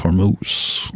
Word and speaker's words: Hormuz: He's real fired up about the Strait of Hormuz. – Yes. – Emphasis on the moves part.